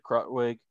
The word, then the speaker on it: Krutwig: Is it Krutwig? – Krutwig, yeah.